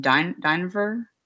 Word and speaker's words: Dynevor: Dynevor.